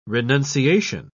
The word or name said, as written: renunciation